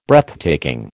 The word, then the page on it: Breathtaking: American English